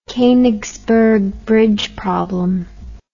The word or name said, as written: Konigsberg bridge problem